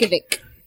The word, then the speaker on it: Yankovic: Yankovic.